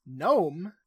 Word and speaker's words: Nome: Nome, which is, honestly, I'm glad that this little joke is in here because it gives me a pronunciation guide. – Truly. – Amos is like, no, not gnomes. I hate gnomes. They smell horrible. Nome, as in a district, a region.